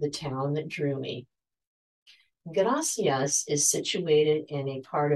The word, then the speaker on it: gracias: The pronunciation of the single R in Spanish, a motion called the alveolar tap, makes the word gracias roll off your tongue.